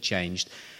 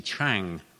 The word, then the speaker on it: Trang: And she then spoke to a lady called Chang, T-R-A-N-G, Chang. Can't pronounce it properly, Chang.